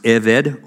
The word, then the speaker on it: eved: It's pronounced eved or plural uh, avadim, and this is the word that means slave.